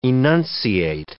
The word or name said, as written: enunciate